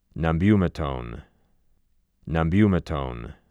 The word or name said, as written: Nabumetone